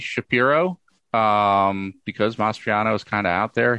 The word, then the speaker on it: Mastriano: Is it Mastriani? Is that how you say it, the Republican candidate? – I think it's Mastriano, but yeah.